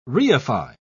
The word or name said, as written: reify